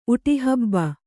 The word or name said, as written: ಉಟಿಹಬ್ಬ